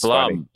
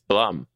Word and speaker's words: Blum: But you know, she probably would have wrote Bloom. So she probably would have wrote Bloom. – Right, or Ume. Okay, you're right. – Bloom. – Wow. It is Blum. It's Blum. It's I've been saying it wrong for so long.